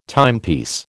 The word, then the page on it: timepiece: American English